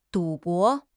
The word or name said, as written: 赌博